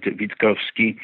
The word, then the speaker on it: Witkowski: You would spell it Witkowski, but I spell it Vitkowski.